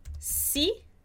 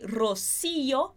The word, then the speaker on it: Rocío: Porque se eu não coloco o acento no i, eu falaria Rocío. E aí a sílaba forte iria mudar nessa palavra. Então, para fazer Rocío, eu preciso colocar esse acento e a gente acaba separando na hora de pronunciar. Rocío. É diferente que se eu falo Rocío, perfeito? E aqui é Rocío.